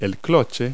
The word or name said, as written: cloche